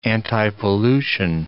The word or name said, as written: antipollution